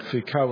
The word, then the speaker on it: Foucault: Correct me if I'm wrong, but Foucault or something, I don't know how to say it. Is that right? Is that how you say it? Good.